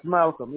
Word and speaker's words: Malcolm: Yes, Malcolm, yes. – And is that M-A-L-C-O-M? – C-O-L-M.